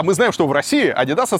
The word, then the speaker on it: Adidas: Вообще правильно говорить Adidas. Adidas, вот, если вы хотите быть правильным, да.